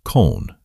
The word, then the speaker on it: Köln: we reached Cologne, or Köln, as the Germans spell it and pronounce it.